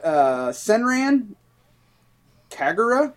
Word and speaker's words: Senran Kagura: You got Senran Kagura.